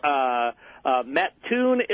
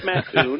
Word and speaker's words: Mattoon: Well, you're putting the emphasis on the wrong syllable, my friend. We are in beautiful Mattoon, Illinois, kind of like Spittoon, but it's Mattoon.